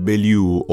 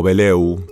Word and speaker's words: Belew: que es Adrian Bellew, o Beleu, como les guste pronunciarlo, Em, que el tipo tocó con Frank Zappa, después se fue a King Crimson, tuvo formaciones propias.